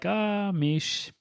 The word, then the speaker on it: Miche: Miche rhymes with quiche, sheesh.